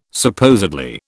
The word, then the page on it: supposedly: British English